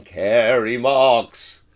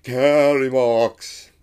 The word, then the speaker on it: Kerry Marks: And I'm Kerry Marks, which is, I've established, is the correct way to say it. Very hard to write it so people read it properly. – Yeah, because you need both big handwriting but also wobbly handwriting. – Yeah, I try and write it so it goes downwards and then back up again. Starts big, Kerry Marks. I didn't even say it right myself then.